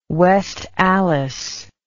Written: West Allis

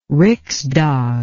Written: Riksdag

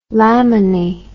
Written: lamini

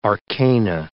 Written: arcana

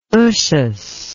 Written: Ushas

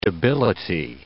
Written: debility

